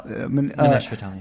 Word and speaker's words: Nimish Patel: Nimish Patel.